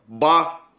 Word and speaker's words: бала: И они платили ежегодный налог Балла, Бала. Внимание, ударение на первый слог, это очень важно, потому что с шумерского это переводится как очередь. Если мы поставим неправильное ударение, это получится чье-то имя.